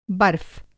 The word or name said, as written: बर्फ़